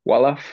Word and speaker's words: Wolof: Wolof, Wolof.